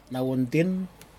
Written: nauintin

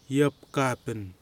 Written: Yapkapin

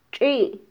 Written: txi

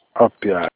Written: Opia

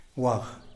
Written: waj

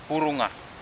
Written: burunga

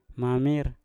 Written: mamir

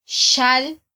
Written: xal

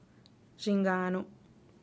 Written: zhinganu

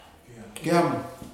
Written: aneityum

